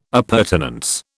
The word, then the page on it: appurtenance: British English